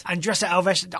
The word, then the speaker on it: Andressa Alves: Andressa Alves. I really enjoy watching her. So, ooh, your pronunciation was solid there, my friend.